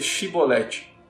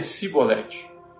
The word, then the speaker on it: Shibolete: Se o homem negasse, eles o mandavam dizer Shibolete. Se ele era de Efraim, dizia Sibolete, pois o povo de Efraim não consegue pronunciar esta palavra corretamente.